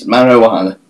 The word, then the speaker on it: marijuana: Marijuana. It's marijuana. Silent J.